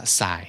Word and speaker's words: xi: ตัว xi นะครับออกเสียงว่าสาย